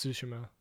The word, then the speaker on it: Tsushima: Tsushima. – Tsushima. – I don't think you say the T. I think it's just Tsushima.